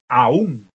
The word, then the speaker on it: aún: Esto se distingue de aún. Aún, ¿de acuerdo? La tónica está sobre la A.